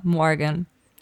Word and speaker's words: Morgan: Also tatsächlich, von mir aus könnte es auch deutsch aussprechen, aber richtig ist es Morgan. – Maya so Morgan. – Mit diesem super amerikanischen Morgan.